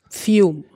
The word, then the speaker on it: FIUM: Wie spricht man euch richtig eigentlich aus? – FIUM.